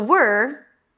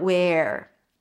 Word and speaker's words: were: By the way, to pronounce it, you start with a W, make sure that there is no vowel in between, and you pull the tongue in for the R. Were, not where.